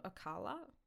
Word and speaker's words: Ocala: So Rowling stole another car and headed for, I think it's called Ocala, O-C-A-L-A. Sorry. I didn't Google, like, pronounce any of this stuff. – That sounds right.